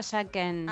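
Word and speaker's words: Jacquin: J-A-C de casa, Q de queso, U-I-N. – Bueno, Gabriela. – ¿Jaquín se pronuncia? – En realidad se pronuncia Jaquen. – Ah, Jaquen. – Es francés, pero nadie lo pronuncia así.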